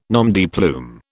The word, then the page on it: nom de plume: British English